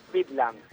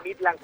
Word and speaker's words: Midland: Se pronuncia como se escribe, con D, Midland. El tema es que el futbolero le dice Midland con T muchas veces.